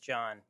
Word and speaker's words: John: You are, but it's just John.